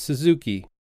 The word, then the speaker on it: Suzuki: Sure, it's Suzuki. – Suzuki. Could you spell that, please? – Yeah, it's S-U-Z-U-K-I. – S-U-Z-U-K-I.